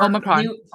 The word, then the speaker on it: Omicron: Is it Omni, whatever, how you ever pronounce it? The Transformer. – Omicron. – Omicron. There we go.